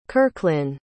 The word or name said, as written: kirklin